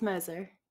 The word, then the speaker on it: Moser: Yes, Moser.